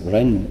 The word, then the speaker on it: Ren: Wenn es aber so etwas bedeutet, wie das, dieses konfuzianische Zeichen, übrigens spricht man es Ren aus oder so ähnlich, also R-E-N wird es transkribiert, Ren, aber wie das mit den vier Tönen ist im Chinesischen, weiß ich nie.